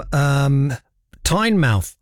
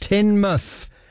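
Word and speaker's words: Teignmouth: She says, "Hi, writing to you from Tynemouth." I'm saying it wrong deliberately. In Devon, as I shout at the screen, it's pronounced Tynmouth.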